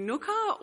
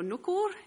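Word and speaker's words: Ngukurr: I'm on my way to Nooka, or Nookur, if you're kind of pronouncing it in the local way, up in the Northern Territory.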